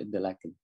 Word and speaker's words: Interlaken: Interlaken đọc thì tiếng Mỹ Interlaken, mà bên đây đọc là Interlaken, tức là liên hồ, một cái hồ Thun và một cái hồ Prien.